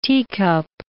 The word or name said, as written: teacup